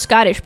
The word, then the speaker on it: Schottische: I think it's just pronounced Scottish, but it's spelled S-C-H-O-T-T-I-S-C-H-E.